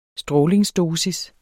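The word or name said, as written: strålingsdosis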